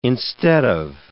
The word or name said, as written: instead of